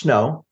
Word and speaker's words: SNO: So SpyNet Online, and it's pronounced Snow. In the book, we pronounce it Snow, the Snow Alliance.